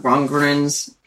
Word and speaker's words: Rundgren: Now, let's get back to talking about Rundgren's book here. I hope I pronounced the name right again.